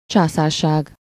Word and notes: empire: /ˈɛm.pa.ɪɚ/; /ˈɛm.pa.ɪə/

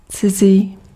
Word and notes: strange: 'streɪn.dʒ

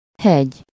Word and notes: montagne: /mɔ̃.taɲ/

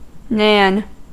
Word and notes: nan: /næn/; /nɑːn/; /nɑn/

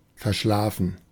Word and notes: sleepy: American English

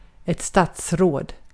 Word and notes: Minister: /mɪˈnɪstɐ/

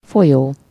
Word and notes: folyó: /ˈfojoː/; /fo.li.oː/

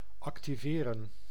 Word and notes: actionner: France; [ak.sjɔ.ne]

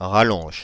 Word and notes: rallonge: Paris; /ʁa.lɔ̃ʒ/

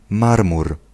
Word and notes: marbre: /maʁbʁ/; France

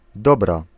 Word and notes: dobra: /ˈdɔ.bra/

